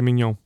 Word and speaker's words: mignon: So it's a filet of the tenderloin, filet mignon.